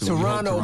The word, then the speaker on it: Toronto: Toronto, man, the last T is silent.